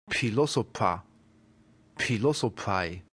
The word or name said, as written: philosopha